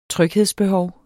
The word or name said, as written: tryghedsbehov